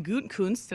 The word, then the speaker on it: Gutekunst: He seems particularly unhappy with their fourth-year GM, Brian Gutekunst. Did I say that right, Wes? – I believe so.